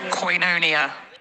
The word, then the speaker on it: koinonia: And that's true because my sister is a ordained minister and I checked in with her to make sure that was true. Even the pronunciation. – Koinonia. – Nailed it.